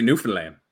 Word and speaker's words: Newfoundland: Is it Newfoundland? Newfoundland? How do you say it? – They would say Newfoundland, because they kind of sound like Irish.